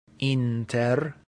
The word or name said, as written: inter